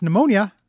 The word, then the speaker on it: pneumonia: That's pneumonia. That's a silent P.